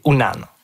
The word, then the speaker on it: Unan: Puis le vrai mot Innu, c'est Unan. Ou parfois prononcé « Wanan ».